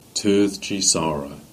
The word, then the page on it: Sarahs Foundary: Cornish